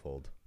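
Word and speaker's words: Folde: And the third marshal of the Riddle Mark was based at the Eldberg in the Foldday. Is that how you say it? – No, Fold. – Fold in the Fold, yeah.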